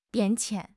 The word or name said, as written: 变迁